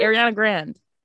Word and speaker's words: Ariana Grande: Yeah, it's Ariana Grande. Just Grand? No, like, oh, you know, Grand? Like, the word Grand?